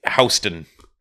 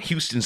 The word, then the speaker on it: Houston: You know that street in, there's a street in New York called Houston, and it's literally just Houston. But it's just not pronounced that way.